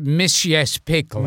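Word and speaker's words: Mr. Mxyzptlk: How do you say that again? – The way it was pronounced to me for the cartoon was Mischievous Pickley.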